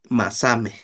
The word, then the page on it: masamej: Náhuatl